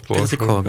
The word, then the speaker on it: Petrichor: Ja, Petrichor, doch. – Petrichor, ja.